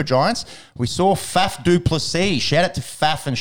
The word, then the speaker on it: Plessis: Plessis rhymes with Pepsi.